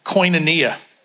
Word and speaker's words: koinonia: That word partner or partnership comes from a Greek word that's pronounced koinonia.